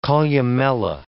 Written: columella

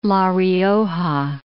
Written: La Rioja

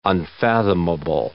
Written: unfathomable

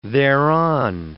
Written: thereon

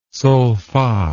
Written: solfa

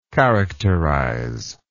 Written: characterizer

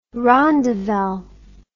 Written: rondavel

